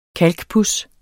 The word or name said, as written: kalkpuds